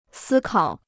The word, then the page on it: 思考: sī kǎo